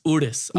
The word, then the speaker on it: Urus: Okay, there's different ways. – Urus? – Like, Urus. And then I guess for us, we could say Urus. That's what I say. But people at Lambo got mad at me. They're like, it's Urus.